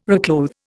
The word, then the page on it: reclothe: Indian